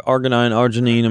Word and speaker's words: arginine: It says it contains the amino acid arginine. Is that my pronouncing that right? – Yeah, arginine. I mean, that's arginine.